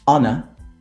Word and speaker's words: honor: There are only four words in which the H is silent. And obviously, the, the words that are either compound or, or that come from these words. And they are our, honor, honest, air.